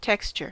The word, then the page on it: texture: American English